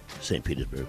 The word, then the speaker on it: St. Petersburg: St. Petersburg, Florida, July the 31st, at the National Guard Armory, St. Petersburg, Florida. I said it right. Mm. It's correct.